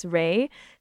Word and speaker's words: Ray: It's spelled R-A-Y-E-R-A-Y-E-S because Ray is short for Raylene, which is my name. Because Sue, when she introduced me at the start, it's Ray.